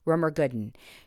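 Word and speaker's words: Rumer Godden: Her name is spelled R-U M-E-R, Rumer, and then Gooden is G-O-D-D-E-N, Rumer Gooden.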